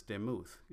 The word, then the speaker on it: demuth: Likeness in the Hebrew is demuth, D E M U T H, and it's a feminine.